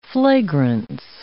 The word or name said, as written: flagrance